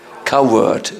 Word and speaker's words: coward: Don't be a cowherd. How do you say in English? Coward. Yes, not cowherd. Coward.